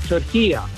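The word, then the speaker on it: Türkiye: At the end is a short A. It's not a long A. In the Arabic language, it's called turkiya. So you could say turkiya. – You and I are making it a hard A. Turkey A, and it's turkeya.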